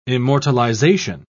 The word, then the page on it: immortalization: British English